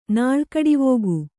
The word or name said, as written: ನಾಳ್ಕಡಿವೋಗು